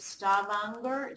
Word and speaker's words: Stavanger: Chumning is a professor at University of Stavanger. Did I pronounce that right? – Yep, that's okay. Very good.